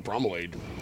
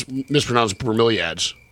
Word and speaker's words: bromeliads: Well, now I'm looking up bromelade. Oh, that's what it is. It's how I mispronounce bromeliads, which is a plant.